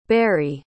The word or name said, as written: Berri